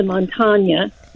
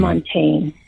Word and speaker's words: Montaigne: Um, a lot of people look to Michelle de Montaigne. Well, that's not. I'm not saying it right de mm-hmm. Montaigne, right. Michel de Montaigne, mm-hmm.